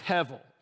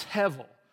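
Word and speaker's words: hevel (הבל): The Hebrew word is hevel. It's H-E-B-E-L, but the B is pronounced the V. So it's hevel.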